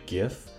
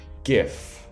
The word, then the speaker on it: GIF: A lot of people say, oh, it's a hard G. It's a hard G because graphic is also a hard G. And since we're dealing with an acronym, we should respect that graphic is a hard G and therefore GIF should be a hard G.